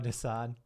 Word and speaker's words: Desaad: Desaid. – Oh, Desaad.